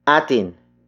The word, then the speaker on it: atin: The next pronoun is atin. Atin. Atin. Atin is spelled as A-T-I-N.